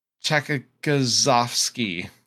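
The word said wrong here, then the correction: Tchaikovsky: I probably butchered that horribly. – Chekovsky. – Yeah. That sounds better than the way I said it.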